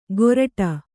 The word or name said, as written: ಗೊರಟ